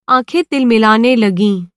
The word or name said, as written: तिलमिलाना